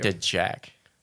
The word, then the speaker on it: Dowagiac: I don't think you pronounce the W. – Dowagiac. – Still pronouncing the W. – Dejack.